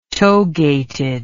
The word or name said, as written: togated